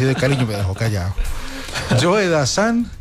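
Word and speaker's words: Joe Dassin: Y entonces presentó a Joe Dazan como si fuera Joe Dazin, como se escribe. Joe Dazin. Entonces yo le dije, no olvides Dianilla, que es Joe Dazan.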